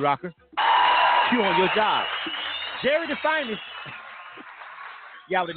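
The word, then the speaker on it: Gerid'Fyniz: Jerry Finest, spelled G-E-R-I-D, apostrophe F-Y-N-I-Z. But it's pronounced Jerry the Finest, so you know.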